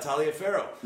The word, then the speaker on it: Taliaferro: That's what happened with Taliaferro. I got the name right. Taliaferro. – Nice. Well done.